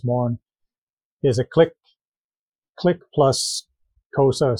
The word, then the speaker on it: Xhosa: They're an offshoot of the Zulus, and a lot of their language is the Xhosa language. And even Xhosa itself, which is spelled X-H-O-S-A, it's a click sound like Xhosa.